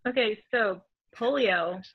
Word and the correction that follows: paleo: properly pronounced paleo, is a form of eating where you only eat, it's like, people call it like caveman diet or whatever.